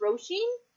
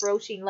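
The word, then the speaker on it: Róisín: not really, because there was that whole arc with the girl whose name I think is pronounced Roisin. Like, they spelled it Rosine, but I think it's meant to be pronounced Roisin, like the Irish Roisin, where this little girl, she was, you know, she was kind of born after an attack on her village.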